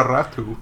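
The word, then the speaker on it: Räty: Yeah, Nora Ratu, yeah. It's a tricky one. It's spelled Rati, R-A-T-Y, but because it's got the two little dots over that A, Tommy, you're supposed to say Ratu.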